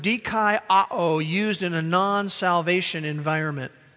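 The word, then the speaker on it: dikaioō: And the main verb is the verb dikaiō in the original language. It's D-I-K-A-I-O-O with a long mark over the second O. D-K-I-A-O.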